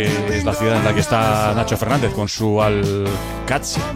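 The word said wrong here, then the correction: Al-Qadsiah: Bueno, se suele decir Al-Qadsiya, pero también hay gente que lo dice Al-Qadisiya. Es que es difícil, ¿eh? Ah. No es fácil, pero Al-Qadsiya es lo más fácil.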